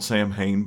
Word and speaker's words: Samhain: Yes, it's spelled Samhain, but it is pronounced, to the best of my ability, anyhow, is Samhain.